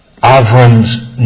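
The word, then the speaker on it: Avram (אברם): God reaffirms the covenant and then He immediately changes Avram's name. In Hebrew, by the way, there's no real B. It's pronounced more like a V. So it's Avram.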